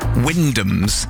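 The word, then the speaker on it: Wymondham: Or the Wyndhams, even if it's spelt Wymundham.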